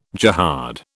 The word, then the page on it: jihad: British English